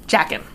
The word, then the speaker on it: Jaqen: Jaqen.